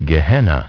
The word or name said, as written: Gehenna